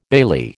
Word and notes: bailee: American English

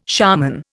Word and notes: shaman: Indian

